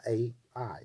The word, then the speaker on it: Ai: Ai is how you pronounce it.